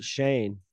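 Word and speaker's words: Chane: It's like Shane, but with a C, right? – Yeah, but they pronounce it Shane.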